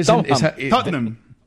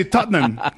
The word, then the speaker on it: Tottenham: Enséñanos, porque es que Tottenham. Tottenham. Son dos sílabas. – Ajá. Es difícil, ¿no? – Nosotros los británicos. Pero... – ¿Solo dos sílabas? – Sí, Tottenham. Tottenham.